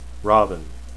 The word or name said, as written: rahvin